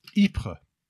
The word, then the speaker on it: Ypres: Ypres.